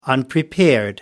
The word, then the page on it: unprepared: American English